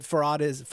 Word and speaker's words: Farhad: Can we just stipulate that that Farhad? Is that how you say it? – Yeah.